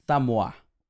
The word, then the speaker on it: Samoa: Samoa.